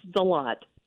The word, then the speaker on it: Deslatte: It's Deslatte.